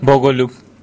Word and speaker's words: Bogoljub: I have problems pronouncing soft L. – Yeah. – So can you say it? – Bogoljub.